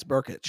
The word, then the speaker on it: Brkic: B-R-K. – I think it's Burkic. – Burkic. B-R-K-I-C. Pronounce that.